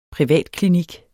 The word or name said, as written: privatklinik